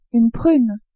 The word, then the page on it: prune: French